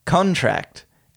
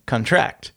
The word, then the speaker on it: contract: Stress on the last syllable is a verb. Examples. Contract and contract. The former is a noun, sign this contract, whereas the latter is a verb, the muscles contract.